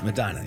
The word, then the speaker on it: Medina: Medina, right? – Medina, yeah.